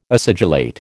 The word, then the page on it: acidulate: American English